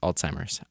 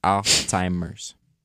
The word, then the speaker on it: Alzheimer's: It also says pronunciation note, Alzheimer's. Alzheimer's. In case you forgot.